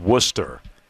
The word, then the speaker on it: Worcester: And I do like, again, I said this earlier in the show, but right there on the pronunciation, it is Worcester.